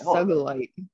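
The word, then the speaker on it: sugilite: It's S-U-G-I-L-I-T-E. – No. – Okay. I'm going to go with Sugalite. – Okay. I like it.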